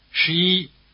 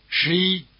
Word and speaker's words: 十一: Say 11 after the speaker and notice that the stress is on the second syllable. – 十一. 十一.